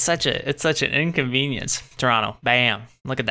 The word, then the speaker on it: Toronto: Say it like a not Toronto person said. If you're from Toronto, you don't say the T.